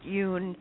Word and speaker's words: Dung: Yeah, it's spelled D-U-N-G. It's pronounced Yun in the Vietnamese version, but most people call it Dung.